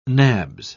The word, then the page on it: nabs: British English